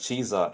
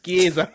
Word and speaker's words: Chiesa: Like, look at Chiesa in his case, Florentina to butchering these people's name, bro. It's Chiesa.